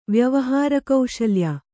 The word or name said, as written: ವ್ಯವಹಾರಕೌಶಲ್ಯ